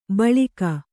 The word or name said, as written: ಬಲಾಕೆ